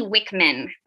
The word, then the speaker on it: Wickman: I'm like, who is this Wiki Wickman, but it's pronounced with a V.